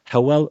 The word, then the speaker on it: Hywel: Hywel rhymes with war, which it doesn't quite in the Welsh language either.